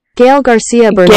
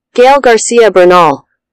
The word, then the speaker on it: Gael García Bernal: Gael García Bernal. – Gael? Say it. – Gael García Bernal.